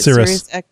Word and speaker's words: Sirius: And it does not affect Bluetooth or Sirius. Did I say that right? – Sirius XM.